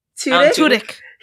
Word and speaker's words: Tudyk: Alan Tudyk? – Tudyk. – He plays so many roles. He has a specific brand now. I'm like, you need someone to play a bird on anything, just call Alan Tudyk, because that's just what he's good at.